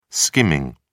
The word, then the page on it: skimming: British English